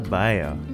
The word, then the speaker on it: bayou: We say bayou. I mean, Fat says bayou too. But in the song, it's bayou. Just like how New Orleans is New Orleans. That's how you pronounce it without it being in a song. But in order for it to rhyme with anything, it's got to be New Orleans.